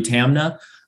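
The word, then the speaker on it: Taamneh: Google, Twitter v. Tamna, I actually believe is pronounced,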